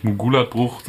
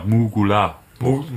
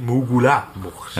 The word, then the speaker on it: Mugula-Bucht: Die sind ja eigentlich um die Silben, also nicht Mugulat-Bucht, sondern eher Mugula-Bucht. – Mugula-Bucht. – Ja, das musst du wie zwei getrennte Wörter lesen.